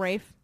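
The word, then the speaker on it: Ralph: How do you say his name? Rafe. – Rafe Fiennes.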